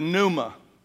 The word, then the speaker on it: pneuma: pneuma, spelled with a P, by the way. Pneuma, like pneumonia or pneumatics, where we get our English words for like air and wind, all right?